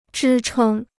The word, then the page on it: 支撑: zhī chēng